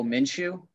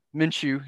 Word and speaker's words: Minshew: Oh, Minshew. – Minshew.